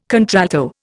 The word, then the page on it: contralto: Indian